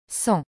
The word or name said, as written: 100